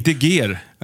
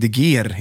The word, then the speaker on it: de Geer: Sveriges första statsminister, Louis de Geer. Inte de Geer alltså, utan de Geer. – Ja. – Som man uttalar det.